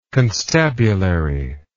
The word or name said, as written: constabulary